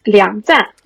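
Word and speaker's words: 梁赞: I think in China it's uh, pronounced as Liangzan.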